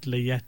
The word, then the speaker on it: layette: It's the word layette. The layette is, it's spelled L-A-Y-E-T, and then there's a T-E at the end, which is like almost silent. You don't really pronounce it. It's just pronounced as layette.